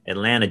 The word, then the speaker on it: Atlanta: Atlanta, Georgia. Right? – No? You did good because you didn't say the second T in Atlanta. And so that's like how you know you're saying it right.